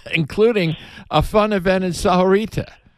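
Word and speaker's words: Sahuarita: And I have to remind myself that Sahorita is four syllables.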